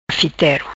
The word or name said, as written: Fitero